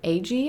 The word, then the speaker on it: Agee: But then John actually tagged me in one of her more recent series on her podcast for Lauren Agee. Am I pronouncing that right? – Yep, I always look at it and my brain wants to say something different. It's spelled A-G-E-E.